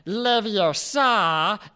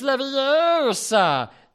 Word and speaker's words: Leviosa: She was all like, it's not Leviosa, it's Leviosa.